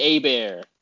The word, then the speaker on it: Hebert: Uh, oh, it's Hebert. Who's Bobby Hebert? That's what I'm thinking about. – Silent H. – Yeah, because he, he, was like, uh, not Bobby tell. Herbert, Bobby Hebert.